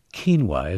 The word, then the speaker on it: quinoa: By the way, it's pronounced quinoa, isn't it? – Quinoa. – Yeah. – Yes. – I botched that nicely.